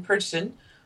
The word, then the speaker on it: Perchten: So that's spelled P-E-R-C-H-T-E-N. Perchten. – Perchten's pretty close, yeah.